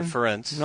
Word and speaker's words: Ferentz: Or Ferentz. – No, I know that one. It rhymes with parents.